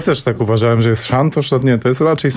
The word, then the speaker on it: Santos: Po portugalsku pani nie powiedziała tak, Santos. – Nie. – Bardziej takie Santos.